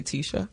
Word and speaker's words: Tisha: Well, actually, Mr. Gatewood, I have to tell you, you've been calling me Tisha for all this time, but it's actually Tisha. – Tisha. – But it's okay. – Hey, can I say it's my southern accent? – No, I've been getting Tisha because it's spelled T-I-S-H-A. – It's Tisha. I've been getting Tisha my entire life. My best friend of like 35 years, her mother still calls me Tisha to this day.